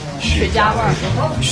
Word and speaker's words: 雪茄: 我怕呛的人可能不行，这里面是很浓的雪茄味儿。 – 雪茄的茄是个多音字，除了茄子的茄这个读音啊，古语当中的一声的茄的意思呢，是指荷叶的茎干。